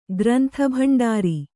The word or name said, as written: ಗ್ರಂಥಭಂಡಾರಿ